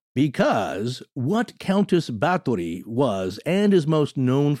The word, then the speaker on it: Bathory: Yeah, she was a Hungarian noblewoman from the family of Bathory. It's spelled B-A-T-H for us Western English speakers. But I believe it's just the T pronunciation here.